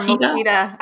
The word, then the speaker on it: Mokita: Am I pronouncing it correctly? – You are Mokita.